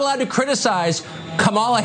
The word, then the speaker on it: Kamala: Her name is pronounced comma like the punctuation mark, la, Kamala.